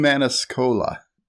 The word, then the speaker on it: Maniscalco: Sebastian Maniscalco.